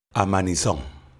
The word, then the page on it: Amanisawng: LLLL